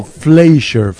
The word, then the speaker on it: Fleischer: Leon Fisher. Es un pianista legendario. Adelante, Nicolás. – Mire, Leon Fisher fue, de hecho, denominado... – Fleischer. – Bueno, pero es que es la pronunciación.